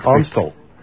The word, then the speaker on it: Amstel: That's Amstel.